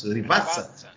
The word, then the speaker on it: Rivazza: O trecho de aferição vai ser ali é, depois da Rivazza, é assim que se pronuncia? Rivazza? Rivazza.